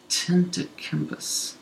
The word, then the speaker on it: Antetokounmpo: Antetokounmpo.